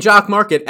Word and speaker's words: Jock Market: And Jock Market, as it rhymes with stock market, is exactly that.